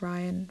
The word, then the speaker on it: Ryan: It's just look for Florence Ryan, Florence and then Ryan, R-Y-A-N.